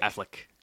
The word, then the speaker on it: Affleck: Ben Affleck. – Affleck, like the insurance company? – Affleck, whatever. Is that how you pronounce it? Affleck? – Affleck.